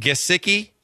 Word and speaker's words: Gesicki: Gesicki? – Yeah, that's him.